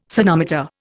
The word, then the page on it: sonometer: Indian